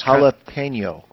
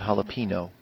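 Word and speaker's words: jalapeño: It's jalapeno, not jalapeno. Just throwing that out there for you in case you ever want to learn to say it right.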